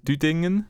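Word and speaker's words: Düdingen: You recorded sounds at 11 different locations, presuming around, is it Dudingen? Am I pronouncing that correctly? It's another one I should have checked. – Yeah, it's the city or the village. It's called Dudingen.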